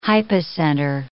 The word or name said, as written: hypocenter